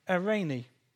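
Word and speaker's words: eirene (εἰρήνη): if I pronounce this right, eirene. I'm looking at Philip. Is that all right? That'll do.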